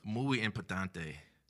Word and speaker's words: muy importante: Muy importante.